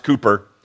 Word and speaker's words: Cowper: His name is William Cooper, although it's spelled Cowper, but they, it's pronounced Cooper.